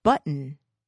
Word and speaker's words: button: I said button. Notice I don't really pronounce that T. Button. In fact, I omit it.